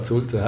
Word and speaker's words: Zulte: Ja, Zulte, heisst Zulte.